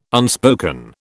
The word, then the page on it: unspoken: British English